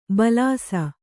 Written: ಭುಲ್ಲಿಸು

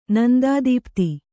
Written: ನಂದಾದೀಪ್ತಿ

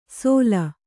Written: ಸೊಲ್

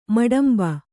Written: ಮಟಂಬ

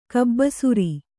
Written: ಕಬ್ಬಸುರಿ

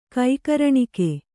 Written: ಕೈಕರಣಿಕೆ

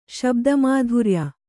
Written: ಶಬ್ದಮಾಧುರ್ಯ

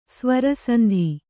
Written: ಸ್ವರಸಂಧಿ

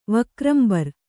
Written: ವಕ್ರಂಬರ್

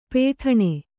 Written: ಪೇಠಣೆ

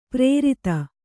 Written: ಪರಾರ್ಧ

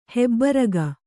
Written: ಹೆಬ್ಬರಗ

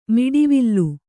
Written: ಮಿಡಿವಿಲ್

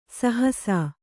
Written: ಸಹಸ

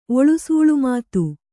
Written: ಒಳುಸೂಳುಮಾತು

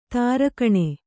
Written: ತಾರಕಣೆ

